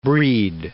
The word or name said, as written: Brighid